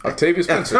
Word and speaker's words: Octavia Spencer: Octavia Spencer.